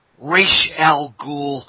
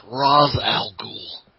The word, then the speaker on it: Ra's al Ghul: The first one was just okay, but it's pronounced Ra's al Ghul, not Ra's al Ghul.